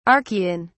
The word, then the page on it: Archaean: British English